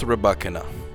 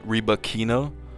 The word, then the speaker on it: Rybakina: And by the way, it's pronounced Rybakina, not Rybakina.